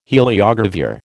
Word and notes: heliogravure: American English

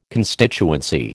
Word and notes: constituency: American English

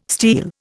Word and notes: steal: Indian

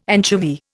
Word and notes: anchovy: Indian

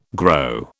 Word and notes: grow: British English

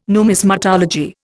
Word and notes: numismatology: Indian

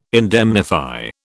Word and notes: indemnify: British English

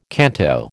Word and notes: canto: American English

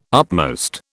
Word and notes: upmost: British English

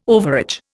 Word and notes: overage: Indian

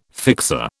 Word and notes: fixer: British English

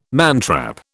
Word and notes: mantrap: British English

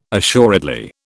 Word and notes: assuredly: British English

